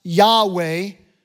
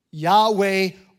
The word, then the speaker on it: Yahweh: YHWH, pronounced Yahweh, or JHVH, pronounced in English as Jehovah. Yahweh or Jehovah.